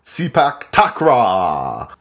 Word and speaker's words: Sepak Takraw: I'm talking about, and I'm going to butcher the pronunciation, Sepak Takraw. – No, that was perfect.